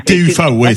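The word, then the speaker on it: TÜV: Le TUFA, c'est ça. TU avec un umlaut et un V, exactement. – Voilà, exactement. Ça s'écrit TUVE, mais ça s'appelle le TUF.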